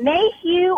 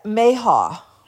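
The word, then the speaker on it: mayhaw: Which is it, Mayhew or Mayha? How do you pronounce it? – Oh, okay. Uh huh. – How do you pronounce it? I believe it's Mayha. – Okay, Mayha.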